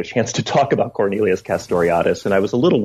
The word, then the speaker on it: Castoriadis: And here you're drawing on the work of the Greek scholar Cornelius Castoriadis, if I'm pronouncing it correctly. – Castoriadis, yeah.